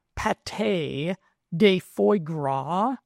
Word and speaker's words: pâté de foie gras: The best sources being shellfish, so that would be cooked clams, oysters, and mussels, beef liver, like liverwurst sausage, pâté de foie gras, and I am a year one high school culinary student, so that is the correct French pronunciation of that dish,